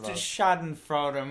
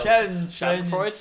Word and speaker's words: Schadenfreude: It's a Schadenfreude moment. I didn't pronounce that right. – No, you've got the problem now. – Schadenfreude.